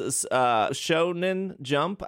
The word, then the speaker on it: Shonen Jump: After that is Shonen Jump.